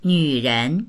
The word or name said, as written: nv3ren2